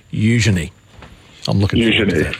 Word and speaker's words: Eugenie: Eugenie. – At least we got the name right. Heavy on the U, and quick on the Je. Me. – Okay. Eugenie.